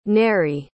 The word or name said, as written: Nery